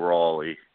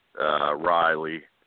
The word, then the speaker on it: Rawley: Big Cass is my version of Nate's Baron Corbin and Nate's, to a lesser degree, Mojo Rawley, Riley, even though it's spelled Rawley. I don't know what the fuck to say about that, other than Alex Riley was R-I-L-E-Y, and they say Mojo Riley.